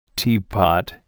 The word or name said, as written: teapot